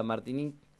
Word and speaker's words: Martinique: Como es un nombre francés, sería Martinique.